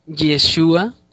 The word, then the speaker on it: Yeshua: En vez de que pronuncie Yeshua, como está en el original, pronuncia Jesús. Pero esto fue de la traducción del griego hacia el alemán.